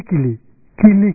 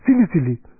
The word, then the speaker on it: Kili Kili: You are supposed to, that's the correct way to pronounce Kili Kili. And that person pronounces Sili Sili, Sili Sili. And this person saying a long time. So then somebody says, oh, you are pronouncing mantra incorrectly. You are supposed to say Kili Kili, not Sili Sili.